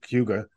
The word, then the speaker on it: Cayuga: So, well, first, let me just correct myself. I was referring to this in the tease from our previous episode as Cayuga, but you've corrected me very gently, and I appreciate it. Cayuga is the pronunciation.